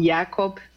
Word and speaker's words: Jakob: Então, a doença de Creutzfeldt-Jakob ou Jakob, né, tem dessas duas possíveis pronúncias,